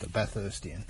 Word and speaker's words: Bathurstian: That's how you say it. It's like just an Ian from Bathurst, a Bathurstian.